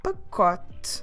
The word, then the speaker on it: pacote: A carton of milk is o pacote de leite. O pacote de leite. Como se escreve pacote? P-A-C-O-T-E. Pacote. Again, we should drop that last E from the word pacote.